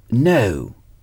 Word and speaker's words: know: C'est la même prononciation que pour le verbe know. I know. Je sais. Je sais. Je sais. I know. Know s'écrit K-N-O-W.